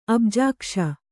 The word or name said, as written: ಅಬ್ಜಾಕ್ಷ